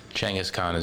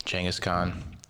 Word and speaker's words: Chinggis Khan: Chinggis Khan is basically the pronunciation of it. Chinggis Khan. That's how what's his name says it.